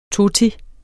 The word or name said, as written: toti